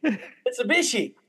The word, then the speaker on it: Mitsubishi: I still didn't say it right. – Mitsubishi. – I can't say it. It's not Mitsubishi. – Mitsubishi. There we go.